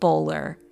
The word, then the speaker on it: Boulware: And just to clear the air, Bowler is spelt like bowl ware, but from what the locals tell me, it is apparently pronounced Bowler, at least with the older generations.